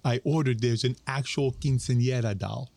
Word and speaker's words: quinceañera: Quinceañera.